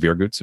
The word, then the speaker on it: Viergutz: Yeah, so my name is spelled, you know, exactly how it sounds, David Viergutz, V-I-E-R-G-U-T-Z.